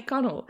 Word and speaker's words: Connell: I get Connell a lot, or O'Connell a lot, or Connolly, O'Connolly. The L is dropped, extra letters are added. It's just Connell, but so often Bridget O'Connell is what I'm addressed as in formal correspondence.